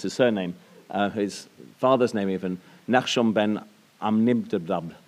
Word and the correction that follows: Aminadav: Aminadav? – Aminadav, I should have practiced that. So Nachshon ben Aminadav,